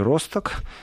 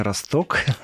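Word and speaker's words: Rostock: Э, прямо под город есть такой Росток в Германии, или хотите Росток. – Борис либо Борис. Э, абсолютно славянское название, если ударение сместить, оно уже будет не совсем славянское название.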